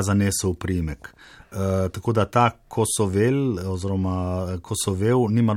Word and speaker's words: Kosovel: Zelo pogosto v medijih napačno izgovarjamo Kosovel. Srečko bi verjetno želel drugače. – Ja, pravilno je Kosovel.